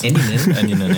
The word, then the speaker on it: Eminem: Eminem, spricht man es richtig aus. – Eminem, ja.